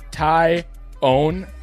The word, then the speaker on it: Taillon: That's actually how you pronounce it, is Taillon, like you own a tie.